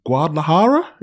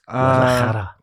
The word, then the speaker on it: Guadalajara: No, this time we're going to Guadalajara. Is that how you pronounce it? – Yes. – Guadalajara.